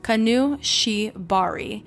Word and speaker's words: Kanashibari: In talking to a few of my Japanese friends, I was informed that Kanashibari, sorry if I'm mispronouncing that, a Japanese sleep demon, oh here it is, pronounced by my friends is Kanushibari, has many forms, and one of them is where the demon comes for the babies of pregnant women in their sleep.